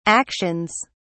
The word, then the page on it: actions: British English